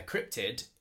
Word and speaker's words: cryptid: how do I say it? Say it again. – Cryptid. – Cryptid. – Cryptid. So it's C-R-Y-P-T-I-D.